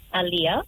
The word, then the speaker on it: Aaliyah: Aaliyah. – How do you spell that, Aaliyah? – A-L-L-I-A-H? – A-A-L-I-Y-A-H. A-L-I-A-H.